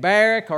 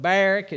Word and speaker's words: Barak: There's Barak, and Barak or Barak, it's pronounced both ways sometimes, but Barak is how I'm going to say it tonight.